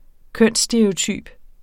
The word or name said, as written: kønsstereotyp